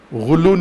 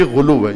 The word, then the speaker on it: غلو: غلو نہیں، غلو پنجابی تلفظ ہے، عربی تلفظ کریں غلو، سب کہیں غلو۔